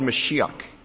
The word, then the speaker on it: Mashiach: It's in the Hebrew, it's called Mashiach. That's how they pronounce it in the Hebrew. The way we make it into English is we say Messiah.